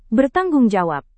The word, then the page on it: bertanggungjawab: Indonesian